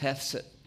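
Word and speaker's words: hesed (חסד): And it's a word that we're going to talk about today, and it's pronounced hesed. Hesed.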